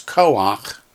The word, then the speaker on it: koach: So, the text says, when the last letter of a word is chait, and the vowel underneath is the patak, ah, the combination is pronounced ach, not cha, like you would expect. So they give two examples. The first one we're pretty unfamiliar with, which is koach, koach.